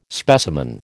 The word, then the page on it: specimen: American English